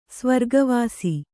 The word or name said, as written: ಸ್ವರ್ಗವಾಸಿ